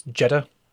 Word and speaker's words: Jeter: You're saying it wrong. It's Jetta.